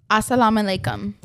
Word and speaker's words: assalamu alaikum: Wait, how do you say it? – Assalamu alaikum.